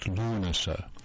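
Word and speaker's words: Lughnasa: Lunasa, or to pronounce it properly, it's Dancing at Lunasa.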